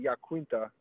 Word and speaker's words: Iaquinta: And to be honest with you, one of the only people that pronounces it, I don't even pronounce it correctly, but Khabib has been pronouncing it correctly when he calls me Yaquinta.